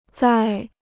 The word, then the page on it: 再: zài